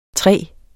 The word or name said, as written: tre